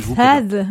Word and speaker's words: Tad: La chirurgie exploratoire découvre des morceaux humains dans le cerveau de Sad. Je sais pas comment le prononcer à la française, Tad ou Sad à l'anglaise. – Alors, dans le film, ils disent Tad, mais je trouve ça très désagréable.